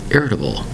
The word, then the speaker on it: irritable: Today's word is irritable. Irritable. Four syllables for the word irritable, spelled I-R-R-I-T-A-B-L-E.